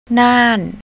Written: nan

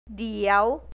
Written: diew

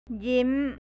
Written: ยิ้ม

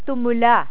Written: tumula

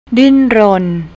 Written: dintrorn

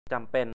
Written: จำเป็น

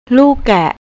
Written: lookkae

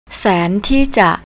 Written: santeeja

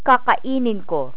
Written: kakainin

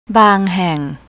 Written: banghang

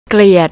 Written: kliat

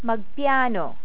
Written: magpiyano

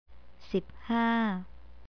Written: siphaa

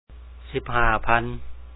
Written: 15000

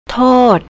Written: tote

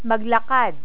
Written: maglakad

